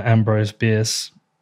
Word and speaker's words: Ambrose Bierce: Um, I think uh, you quoted uh, Ambrose Bierce.